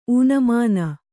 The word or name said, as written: ಊನಮಾನ